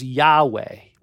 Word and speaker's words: Yahweh: Four consonants that we pronounce as Yahweh, which means I am.